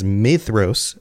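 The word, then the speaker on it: Maedhros: Thank you to those who have corrected me on the pronunciation of the DH. It is Maedros, like TH.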